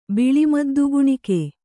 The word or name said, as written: ಬಿಳಿಮದ್ದುಗುಣಿಕೆ